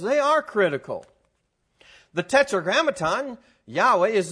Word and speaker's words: Yahweh: The early biblical pronunciation appears to be Yahweh with a labial labial. I learned this at Fuller. They drew a little diagram when I was taking Greek and Hebrew. And, you use the lips, to pronounce the W.